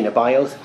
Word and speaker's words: Nebaioth: Most of your Bibles will have it with an I. Most translations do. It's closer to the Hebrew pronunciation, and I find it easier to say Nebaioth.